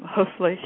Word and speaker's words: Hoeflich: Yes, it's in German. It's Hoflich, and I learned some German too. I lived in Germany for about 2.5 years. In fact, I had worked at one of the universities there in the Department of Physics, and so I had I pronounce my name Hoefle. – And that's spelled H-O-E-F-L-I-C-H.